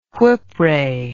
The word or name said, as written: whipray